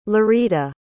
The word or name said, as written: lirita